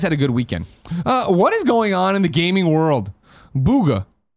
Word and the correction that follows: Bugha: I always say his name wrong, I think. Bugha.